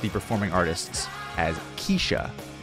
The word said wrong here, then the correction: Kesha: and in fact, it's Kesha. Kesha.